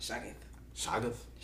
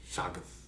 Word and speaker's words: Shoggoth: Shoggoth. I'm probably say it wrong tomorrow though. – Shoggoth? Yep. Like with CH or SH? – SH. – Shoggoth? – Yes. And it ends with a TH. – Shoggoth.